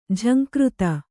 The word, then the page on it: ಝಂಕೃತಿ: jhaŋkřti